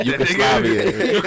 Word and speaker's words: Yugoslavian: Say the word right, Yugoslavian. – Yugoslavian. – I've been drinking again. – Yugoslavians. – So five syllables.